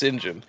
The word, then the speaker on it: St. John: When you see St. John as a name, it's pronounced Sinjin.